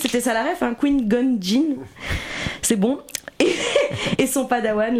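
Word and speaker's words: Qui-Gon Jinn: Sauvé par King Jong-jin et son padawan... – King Jong-jin. – Pourquoi ça se prononce pas comme ça? – C'est Qui-Gon-jin. – Qui-Gon-jin.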